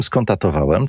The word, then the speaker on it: skonstatowałem: Plany były, drodzy państwo, takie, że rzeczywiście poprowadzę ten program z Warszawy, bo wybieram się do Włocławka, ale ze zdziwieniem skonstatowałem, tak się wymawia to słowo, że z Warszawy do Włocławka jedzie się dłużej niż z Katowic do Włocławka.